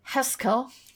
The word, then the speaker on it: Haskell: Que es... se pronuncia Haskell. Haskell.